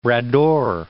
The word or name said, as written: Bras d'Or Lake